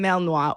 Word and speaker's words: Malinois: Specifically is a Belgian Malinois, right? – Okay. – Belgian Malinois, or they Malinois, people pronounce it differently.